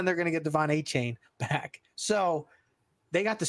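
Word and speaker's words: Achane: They're going to get Devon A-Chain, like he likes to be pronounced, is coming back in a few weeks.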